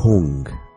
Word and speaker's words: Hung: cuando digamos la sílaba Hung, H-U-N-G y la H es una pronunciación inglesa como hello, Hung, las células se vuelven azules.